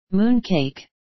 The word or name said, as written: mooncake